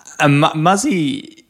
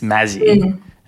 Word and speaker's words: Mazi: Muzzy, it's spelled M-A-Z-Y. Mm. So if you're an Aussie like me, it's Mazzy, but obviously not in your community, and that's not how you want to pronounce it, the business name.